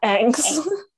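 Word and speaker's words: Ingres: This painting was made in 1814 by Angst. Did I say that right? – I think it's more like a Angst. – Oh, wait, right. – Yeah, Angst. Yeah.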